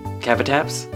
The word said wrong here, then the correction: Kabutops: I mean, I'm not sure I'm the authority on this, but I would say it Kabutops.